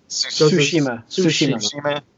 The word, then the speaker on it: Tsushima: Tsushima.